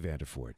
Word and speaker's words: Vanderford: I probably won't answer all the questions, but that's why we have Matthew here, and it's Vanderford. Did I say that right? – Yes, Matthew Vanderford. – Okay. I wanted to make sure. I don't. You never know. Is the R silent? – Yeah, right?